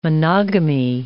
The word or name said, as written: monogamy